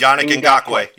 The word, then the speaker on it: Yannick Ngakoue: So we got a guy up there, Yannick Ngakoue. I think I'm saying that right. – Defensive. Yannick Ngakoue, yep.